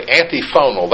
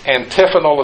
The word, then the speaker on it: antiphonal: Now, some who read that word and don't know what it means would say antiphonal. That means they're against sound choir. No. It's antiphonal is the way it's pronounced.